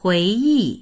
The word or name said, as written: hui2yi4